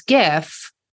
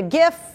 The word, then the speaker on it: GIF: uh, the jar takes a stand in a popular online event saying that the word GIF should be pronounced with a hard G, not a soft one. So GIF, yeah. The creator of GIF has previously said it should be pronounced with a soft G, like the peanut butter brand, right?